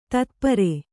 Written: ತತ್ಪರೆ

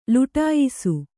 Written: ಲುಟಾಯಿಸು

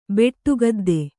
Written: ಬೆಟ್ಟುಗದ್ದೆ

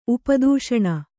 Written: ಉಪದೂಷಣ

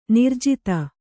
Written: ನಿರ್ಜಾತ

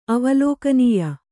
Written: ಅವಲೋಕನೀಯ

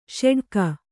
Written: ಷಡಕಿ

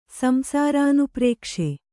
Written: ಸಂಸಾರಾನುಪ್ರೇಕ್ಷೆ

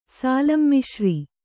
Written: ಸಾಲಂಮಿಶ್ರಿ

